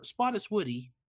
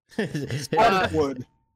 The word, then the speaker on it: Spottiswoode: It's either Spottiswoode or Spottiswoody. – Spottiswoode.